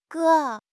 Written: ge3

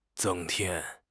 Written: 增添

